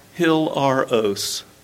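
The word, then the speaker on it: hilaros (ἱλαρός): That cheerful word, Greek 2431, is pronounced hilaros. Hilaros. Hilaros.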